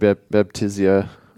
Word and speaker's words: Baptisia: And so sometimes these might be labeled Baptisia, or how do you pronounce it? – Oh, I just said Baptisia.